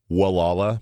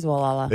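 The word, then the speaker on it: Gualala: It's spelled Wallala, and if I really want to be ding-ding about it, the locals pronounce it Wallala. – It is Wallala.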